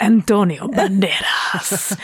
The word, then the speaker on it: Antonio Banderas: You have to say it like that, too, by the way. Who are you talking about? Antonio Banderas.